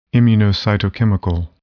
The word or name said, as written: immunocytochemical